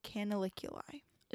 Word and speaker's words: canaliculi: So it drains into something called the superior and inferior lacrimal canaliculi. – No idea what that is. – So let's break it down. It's spelled C-A-N-A-L-I-C-U-L-I, which is canal, and then iculi.